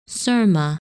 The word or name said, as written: Syrma